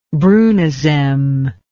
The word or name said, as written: brunizem